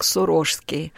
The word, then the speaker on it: Сурожский: Дело в том, что сам владыка произносил свой титул как «Сурожский». И город, в честь которого названа соответствующая епархия, Сурож, и поэтому он делал ударение вот таким образом, Сурожский.